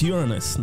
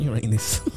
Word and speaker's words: Uranus: It's Uranus, not Uranus.